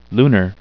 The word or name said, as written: lunar